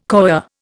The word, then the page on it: coir: Indian